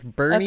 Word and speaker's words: Boerne: And this is in a town, and let me spell it for you, and I want you to guess how it's pronounced. – Okay. – B-O-E-R-N-E. – Bernie. – Yeah. – Really? – It is. It's Bernie, okay.